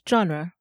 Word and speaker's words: genre: Genre. Let me spell that. G-E-N-R-E. Genre. This is a word from French that we use in English.